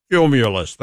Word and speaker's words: Cumulus: I work for the company. I should be able to pronounce it. Cumulus.